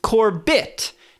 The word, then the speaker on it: Corbett: My surname, Corbett, has been used incorrectly, as it is pronounced Corbett, not Corbett. So like Ronnie Corbett.